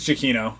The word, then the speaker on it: Giacchino: Or Giacchino, how do you say that? Is that how you say his name? – I think it's Giacchino. That's right.